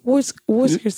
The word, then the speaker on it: Worcestershire: It's not pronounced like that. It's pronounced... Worcestershire sauce.